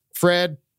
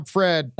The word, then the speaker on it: Fred: Yeah, well, I mean, it was just Fred. Yeah, I'm Fred. It does rhyme with like bread, that. But it's not actually bread.